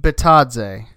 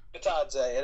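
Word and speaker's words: Bitadze: Do, do we know how to pronounce that last name yet? – Batadze. Batadze at 18.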